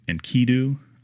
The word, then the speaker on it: Enkidu: I always pronounce the name wrong. I think it's Enkidu, I think is the way that you pronounce it. I'm not 100% sure on the pronunciation.